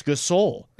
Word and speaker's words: Gasol: And forever, I called him Gasol. – Pau Gasol. And then I finally learned that the brothers were named Gasol. And so because of them, I now will forever be able to pronounce Gasol.